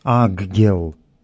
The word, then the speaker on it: аггел: то тогда следует читать, как написано «аггел».